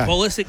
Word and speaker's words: Pulisic: We have a star named Christian Pulisic that everybody loves. We have a guy named... – Say that again. – Christian Pulisic? – Pulisic? Is that how you say that? – Yeah. – Okay. – Pulisic.